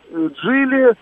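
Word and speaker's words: Geely: Это там, ну, я не знаю, Амода, Джили, ну, может быть, этот, как его еще, Чинган. – Амода, Чинган, да, да. Джили, вы правильно говорите, Черри.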